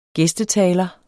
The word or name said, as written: gæstetaler